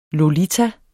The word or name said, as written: lolita